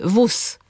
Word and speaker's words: wóz: Mówimy na przykład nóż, mimo że piszemy ż, wóz, mimo że piszemy z, nie widzę gwiazd, mimo że tam jest z i dy, obserwuje bieg, a nie g, bieg es hab.